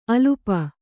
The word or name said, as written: ಅಲ್ಪ